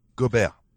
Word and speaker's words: Gobert: How do I pronounce it? – Gobert. – Gobert. – Gobert. – Gobert. Gobert. Say it again. Gobert. – Gobert. – Gobert. – Gobert. – So like Gobert? – Yeah, that's perfect.